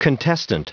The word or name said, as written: contestant